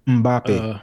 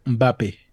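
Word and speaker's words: Mbappé: Mbappe. Uh, Mbappe.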